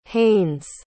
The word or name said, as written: haines